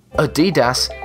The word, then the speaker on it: Adidas: Adidas is the correct pronunciation. – Adidas. – Ah, que Adidas. – Tu l'avais. – Adidas. – Mais Adidas. – Non, j'ai Adidas. Adidas. – Mais ça sonne Adidas. Tout vraiment snob, hein? – Oui, mais c'est parce que c'est allemand.